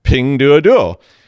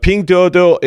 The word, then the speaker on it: Pinduoduo: Pinduoduo. And I said it wrong. I got the tone wrong on the last one. Sorry. But Pinduoduo is a zero day, has a zero day in it, and they were using it to spy on users of the app.